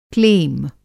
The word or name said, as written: क्लेम